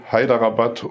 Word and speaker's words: Hyderabad: Neu-Delhi, Hyderabad und Bangalore.